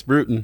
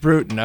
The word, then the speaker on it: Brewton: Coach, did I pronounce that right? – That's Brewton. – It's Brewton, okay.